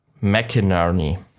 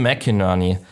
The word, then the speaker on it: McInnerney: Ja, und dann hat sich aber Lord Percy, also unser Tim McKinney. – Tim McKinney hat McInnerney? – Ja, ich hab's mir von Google aussprechen lassen, tatsächlich einmal. – Okay. – Tim McKinney, Moment. McInnerney. Tim McInnerney hat sich entschlossen.